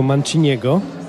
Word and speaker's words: Manciniego: edycji Jazz na Todrą zagra Państwu z kolegami muzykę Henry'ego Manciniego. Tak wymawiajmy to nazwisko.